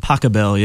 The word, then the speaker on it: Pachelbel: But it says Rob's musical dissertation on classical composer Johann, is it pronounced Pachelbel? – Pachelbel, yeah.